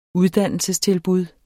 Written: uddannelsestilbud

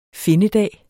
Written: findedag

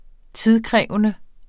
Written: tidskrævende

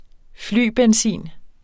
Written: flybenzin

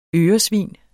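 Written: ØRESVIN

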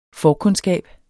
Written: FORKUNDSKABERNE

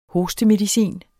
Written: hostemedicin

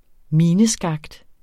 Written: mineskakt

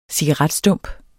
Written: cigaretstump

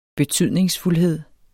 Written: betydningsfuldhed

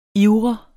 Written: ivre

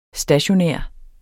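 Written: STATIONÆRT

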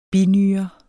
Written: binyre

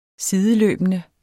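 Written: SIDELØBENDE

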